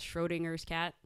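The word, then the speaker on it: Schrödinger's: It's Schrodinger's cat? – Yeah.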